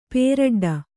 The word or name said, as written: ಪೇರಡ್ಡ